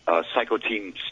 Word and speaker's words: cytokine: That's interesting, because some of these VAERS reports mention exactly what you said about the cytokine storms. I don't know how that word is pronounced. – Cytokine. – Cytokine. Yeah, cytokine storm.